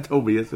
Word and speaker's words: Tobias: Is it Tobias? I hope it's Tobias. It's not Tobias, or is it? No, it's not Tobias. It's Tobias Harris.